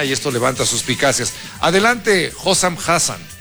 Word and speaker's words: Hossam Hassan: Vamos a escuchar al entrenador egipcio, Hossam Hassan o algo así. – Hossam Hassan.